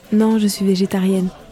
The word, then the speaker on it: végétarienne: So it's végétarienne. – Okay, and because this is a woman, you pronounce the N at the end, right? – Yeah.